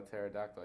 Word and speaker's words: pterodactyl: I said a joke about a pterodactyl. I said, why can't you hear a pterodactyl go to the bathroom? And the joke was, because the P is silent.